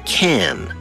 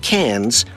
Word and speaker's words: Cannes: Even though it is spelled C-A-N-N-E-S, it is pronounced can, not cans or cons.